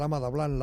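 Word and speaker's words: Ama Dablam: Ese proyecto inminente, como decimos, un viaje a punto de partir hacia Nepal, hacia el Himalaya, para subir al Ama Dablan. ¿Lo he dicho bien? – Sí, sí, perfectamente. Al Ama Dablan, la Dama Blanca se llama.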